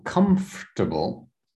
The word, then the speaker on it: comfortable: So let's do so so comfortable. Comfortable. So it's like the or is silent. So it's comfortable like this.